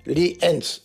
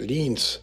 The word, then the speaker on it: Lienz: Gesprochen Lienz. Zwei Silben und nicht, wie ich es gesagt habe, Lienz.